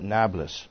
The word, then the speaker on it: Nablus: Then Joshua gathered all the tribes of Israel to Shechem, or Shechem, and there's a city there now called Nablus, which is New City, Neapolis. In Arabic, they don't pronounce the P, so they call it Nablus. It's like the word Naples in Italy.